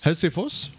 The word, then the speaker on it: Helsingfors: It's how you say it in Swedish. – How do you say it? – Helsingfors. – Helsingfors? – Helsingfors.